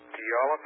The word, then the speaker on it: Puyallup: Puyallup, I believe is the way you pronounce that, Washington. Let me get that right. Puyallup, Puyallup, Washington, 98373.